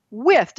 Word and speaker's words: Wythe: Today, George Wythe was spelled With, W-I-T-H. His name is spelled W-Y-T-H-E, but it's pronounced with. So it was spelled W-I-T-H for Trump.